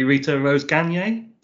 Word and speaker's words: Rita Rose-Gagné: Rita Rose-Gagné.